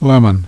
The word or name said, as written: lemon